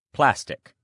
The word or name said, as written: plastic